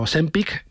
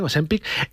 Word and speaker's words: Ozempic: Estos medicamentos como Ozempic, que ahora sí lo digo bien, Ozempic, salieron al mercado para tratar no la obesidad, sino la diabetes.